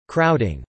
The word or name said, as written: crowding